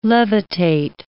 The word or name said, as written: levitate